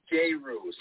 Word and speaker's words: Jeru: And so what Master, is it Jeru? Am I saying that right? – Actually, Jeru. So on the E, it makes it sound like an A, yeah.